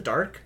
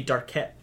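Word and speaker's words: D'Arque: But it's D apostrophe A R Q U E. – Is that how you pronounce it? Dark? – Yeah. – I thought it was gonna be Darquette or like No, Monsieur Dark.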